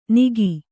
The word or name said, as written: ನಿಗಿ